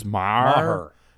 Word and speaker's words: Maher: Is it Maher? Just Maher. – Maher. – You got to get the you got to get that H in the Maher.